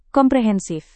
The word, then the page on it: komprehensif: indonesian